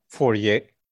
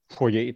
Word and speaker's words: Fourier: Nah, masalah ini sudah ditelaah sejak zaman Fourier. Tahu tidak? Apa? Fourier itu bukan Fourier. bacanya. Jadi? R-nya itu R Prancis kayak foyer, foyer, ya gitulah.